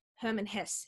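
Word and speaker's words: Hermann Hesse: Herman Hesse.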